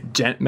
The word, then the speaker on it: Djent: Dent metal. – I couldn't pronounce that. – D-J-E-N-T. Yeah.